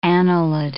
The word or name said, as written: annelid